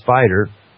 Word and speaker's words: SPDR: The fund, the SPDR Gold Trust, pronounced SPIDER,